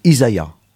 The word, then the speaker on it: Isaiah: Alors, le prénom, c'est Isaiah. – Oui. – Alors le nom, c'est facile. Le nom, c'est Stone. Mais le prénom, c'est Isaiah. Ça s'écrit I-S-A-I-A-H.